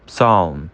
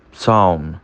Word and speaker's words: psalm: Ó, próxima nós temos psalm, psalm, psalm. E por último, psychology, psychology, psychology, tá? O P, ele é bem levinho ali e a gente considera ele mudo.